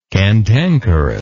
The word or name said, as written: cantankerousness